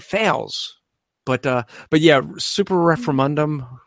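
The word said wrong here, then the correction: Semper Reformanda: or however it's properly pronounced. – Yes, Super Reformanda. That's the one.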